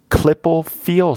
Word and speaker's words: Klippel-Feil: She had been diagnosed with Klippel-Feil syndrome, and I'll admit I don't know that I'm pronouncing that correctly, but it's right. – Klippel is K-L-I-P-P-E-L. Feil is F-E-I-L, for anyone out there who may want to look this up on their own.